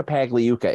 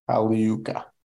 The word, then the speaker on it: Pagliuca: Cheers and a toast to Gianluca Pagliuca. – Pagliuca. – I don't think you pronounced the G before the L in Italian.